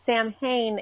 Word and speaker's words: Samhain: I've always been conflicted on how to pronounce his name, because Sam Hain is a pagan holiday, but I don't know if it's what European language it's in, but it's pronounced Samhain.